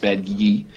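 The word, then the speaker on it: Badguy: But so the basic setup is right after the we're doing a sequel song, Ricky Gervais as Dominic Badgi, spelled bad guy, pronounced Badgi.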